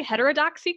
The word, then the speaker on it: Heterodoxy: And there is a women's club at the heart of this storyline called the, I might pronounce this incorrectly, Heterodoxy Club.